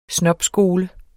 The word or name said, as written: snobskole